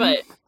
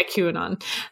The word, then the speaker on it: QAnon: I'm just getting frustrated about it, because it's like, this is how, like, you know, the QAnon stuff started. I probably said that wrong. – QAnon. – Yeah, QAnon.